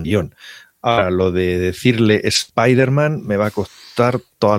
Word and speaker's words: Spiderman: Y en los cómics dicen Spiderman con acento de español o de Madrid. – Con acento en la I, Spiderman. – Bueno, con acento de Madrid, además, has dicho. – Es Drújula. Es Drújula.